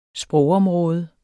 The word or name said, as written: sprogområde